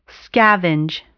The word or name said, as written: scavenge